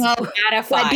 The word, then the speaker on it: beatified: So when I was editing our episode on Carpe Demon, Panda brought to my attention that I kept saying beautified, and the word is beatified.